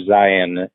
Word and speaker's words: Zeihan: uh, for those who have are not familiar, Peter Zion, it's it's pronounced like Zion National Park, but it's spelled Z E I H A N, and he's got three books out, and they're continuations of each other.